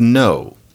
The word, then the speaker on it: know: I mean, for example, there are people who say that we need spelling reform, but even if we do it, the word know, as in to know a fact, should keep the K. The K is hopelessly unpronounced,